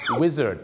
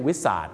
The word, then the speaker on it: Wizard: ออกเสียงว่า Wizard นะครับไม่ใช่ Wizard หรือสุดท้าย